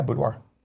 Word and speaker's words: boudoir: Yeah, boudoir.